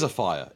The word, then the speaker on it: Afire: I'm assuming it's pronounced Afire. – It is Afire. It is Afire. One word, Afire or on fire.